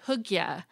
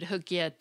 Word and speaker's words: hygge: They also included the Danish word hygge, which means, quote, the practice of creating cozy and congenial environments that promote emotional well being, unquote. Oxford dictionaries actually included hygge too, and their definition notes that it's regarded as a defining characteristic of Danish culture.